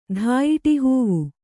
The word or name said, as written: ಧಾಯಿಟಿಹೂವು